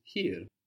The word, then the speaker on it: hier: The word for here in German is hier. – Here. – And this is spelled H-I-E-R. So I-E gives us E, where E-I gives us I.